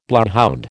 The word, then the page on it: bloodhound: American English